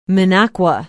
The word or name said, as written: Minocqua